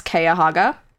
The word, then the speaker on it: Cuyahoga: So I'm going to go with what I think is the correct one, and that's Cuyahoga. – Okay. – Falls. Cuyahoga Falls. It's spelled exactly how you would not think it's pronounced, which is Cuyahoga, and then Falls.